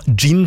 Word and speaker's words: jinn: Gin tonic, ja. Nei. Ja, det skrives med jod. J-I-N-N. Gin. Men også det uttales gin. For på engelsk skrives det med D først. Gin.